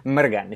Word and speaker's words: Mrgan: Mergen, if you can roll your R's.